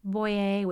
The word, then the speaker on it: Boyer: Boyer, which is my last name, and that's spelled B-O-Y-E-R.